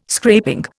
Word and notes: scraping: Indian

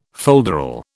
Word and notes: folderol: British English

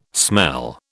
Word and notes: smell: British English

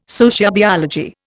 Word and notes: sociobiology: Indian

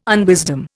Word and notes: unwisdom: Indian

